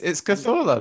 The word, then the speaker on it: Cazorla: It's Cazorla, that's how you say it.